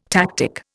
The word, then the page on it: tactic: Indian